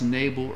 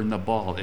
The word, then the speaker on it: Nabal: The word used for foolish is, it's either pronounced Nabal or Nabal. It's N-A-B-A-L.